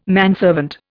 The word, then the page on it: manservant: Indian